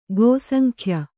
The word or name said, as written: ಗೋಸಂಖ್ಯ